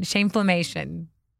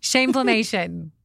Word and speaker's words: Shameflammation: Shameflammation. – That's okay. Really, I made up the word, really, great. So it's like you don't know how you to made pronounce this up. It. – Shameflammation.